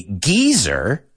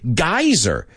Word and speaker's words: geyser: The British say geyser, and Americans say geyser.